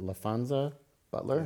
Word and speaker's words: LaFonza Butler: Is it LaFonza Butler? – Yes.